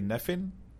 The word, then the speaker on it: Nephin: Today we have with us Daniel, I hope I'm saying it correctly, Nephin.